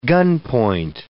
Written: gunpoint

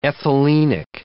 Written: ethylenic

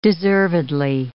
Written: deservedly

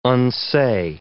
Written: unsay

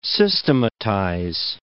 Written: systematize

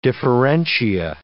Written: differentia

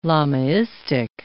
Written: Lamaistic

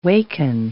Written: waken

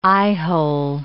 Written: eyehole

